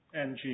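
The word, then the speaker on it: Ng: Mr. Knight? Is that how you pronounce it? – N-G, Your Honor. – N-G. – Yes, Your Honor.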